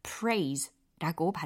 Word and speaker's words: preys: 먹잇감이라는 단어로 P-R-E-Y 거기에다가 복수형으로 S만 붙여준 겁니다. 그래서 praise라고 발음이 들린 거고요.